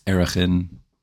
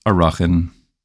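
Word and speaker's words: Arachin (ערכין): is going to be Mesechas Erechin, or more correctly pronounced, Arachin.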